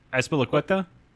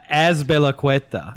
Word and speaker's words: Azpilicueta: Azpilicueta? – What? Say that Azpilicueta. – Again. Azpilicueta. – There's a P in there. It's not a B, but yeah, it's close enough.